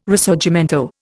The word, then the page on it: Risorgimento: Indian